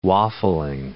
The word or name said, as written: waffling